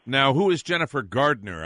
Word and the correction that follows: Garner: I'm not familiar. – Garner. – Oh, yes. – Garner. Bye! – I've never liked that distinction. If you're going to pronounce the name correctly, pronounce it correctly. If we were going to clean your yard and do your lawn, we'd be Gardner.